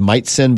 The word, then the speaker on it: Majcen: If you ever look for him, his name is pronounced Mightsen, but it's spelled M-A-J-C-E-N.